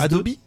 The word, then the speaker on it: Adobe: Adobe. – Adobe, c'est comme ça que ça se prononce en anglais.